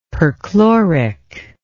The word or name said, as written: perchloric